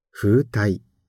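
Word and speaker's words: 風袋: 風体。風に袋と書いて風体と読みます。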